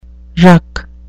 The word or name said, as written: ruck